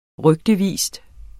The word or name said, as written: rygtevis